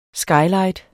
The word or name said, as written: skylight